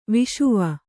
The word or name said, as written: ವಿಷುವ